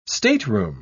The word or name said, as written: stateroom